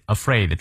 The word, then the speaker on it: afraid: You must not be afraid afraid 重音在后面。